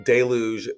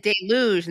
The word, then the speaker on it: deluge: You also said deluge instead of deluge, which is interesting.